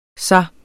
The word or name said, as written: sig